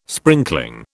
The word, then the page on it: sprinkling: British English